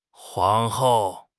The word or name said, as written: 皇后